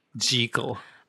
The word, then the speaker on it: Jekyll: of Dr. Jekyll and Mr. Hyde, and you will notice that I say it correctly. Jekyll. – Oh, right. I think that's a thing, but I'm going to keep saying Jekyll.